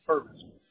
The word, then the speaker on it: Purvis: Purvis. They call me by my last name. – Okay. And how do you say that? How do you spell that? – P-U-R-V as in Victor, I-S, Purvis.